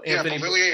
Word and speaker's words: Beauvillier: They got the first goal, you know, the goal by Beauvillier. Is that the right pronunciation? I believe so. – Beauvillier had the first goal, yeah.